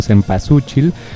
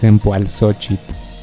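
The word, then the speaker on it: Cempoalxóchitl: En realidad ellos le pusieron Cempasúchil, pero la pronunciación correcta es Cempoalxochitl, que significa la flor de los 400 pétalos.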